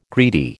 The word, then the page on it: greedy: American English